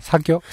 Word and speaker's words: 사귀어: 이게 읽는 거하고 달라요. – 음. – 사귀어라고 읽을 수 없잖아요. – 음. – 그런 거 아시죠? – 네. – 사귀어라고 읽을 수 없어요. – 맞아. 사겨? 사겨? 사겨? 이런 식으로 네.